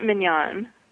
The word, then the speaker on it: Mignon: Well, I pronounce it Mignon. – Mignon. – I don't know if that's right or not in the French, but that's how my family pronounces that's it.